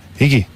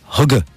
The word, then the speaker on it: hygge: It's spelled H-Y-G-G-E. – Higgy. – Higgy. – Higgy. – It's goddamn Higgy Zeets. – What's Higgy Zeets? – It's you pronounce it Hugga.